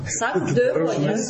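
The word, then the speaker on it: sac de voyage: Сак де вояж, конечно. Только немножко так произносится, так все правильно.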